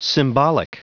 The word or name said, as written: symbolic